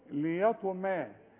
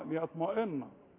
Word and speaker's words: ليطمئن: ليطمئن، لان الميم قبلها طاء تخينة فضيعت الايه الميم، تقولوا ليطمى، ما قلش ليطمى، ليطمئن والنون تضيع.